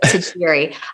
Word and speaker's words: Tajiri: It's kind of a mouthful, but Tajiri.